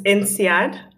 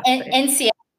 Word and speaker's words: INSEAD: And just tell me, how do you actually pronounce the name of where you went to? Yeah. – It's INSEAD. Yeah. – INSEAD. Okay. Okay.